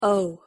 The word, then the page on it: o: English